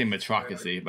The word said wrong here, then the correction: meritocracy: but I can't say it right anyway. – Meritocracy. – Sorry, I was like, I'm missing a syllable.